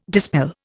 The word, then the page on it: dispel: Indian